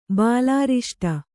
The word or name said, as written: ಬಾಲಾರಿಷ್ಟ